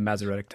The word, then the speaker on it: Masoretic: The Masoretic text? – The Masoretic text.